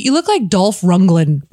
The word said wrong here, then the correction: Lundgren: Lundgren, I think. – D, you look like Golf Lundgren. Am I saying it right? – You're close enough.